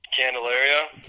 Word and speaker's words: Candelario: You know they have Cal- Calendarano Rio I'm saying it wrong. Candelario. Candelario, thank you.